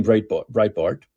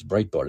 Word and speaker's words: Breitbart: So what's happened recently in Canada, this was written today in Breitbart. Why did I say Breitbart? It's Breitbart, isn't it?